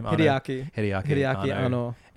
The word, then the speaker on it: Hideaki Anno: Hideaki Anno.